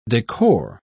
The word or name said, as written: decor